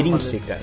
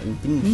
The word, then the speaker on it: Intrínseca: Intrínseca. – Intrínseca. Intrínseca.